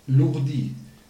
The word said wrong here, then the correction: Ludy: Ludi. – Ludi, wie schreibt man das? – L-U-D-Y.